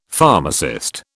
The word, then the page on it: pharmacist: British English